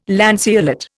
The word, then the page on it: lanceolate: Indian